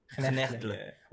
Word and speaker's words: Knechtle: How do you say your name, actually? – Knechtle. – Wait, so it's, at the end it's an E. It's like Knechtle.